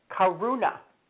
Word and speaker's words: Karuna: Okay, the second one is Karuna, K-A-R-U-N as in Nicholas, A, Karuna. So you're sitting under your tree, somebody hands you a beer, it's not a Corona, it's a Karuna.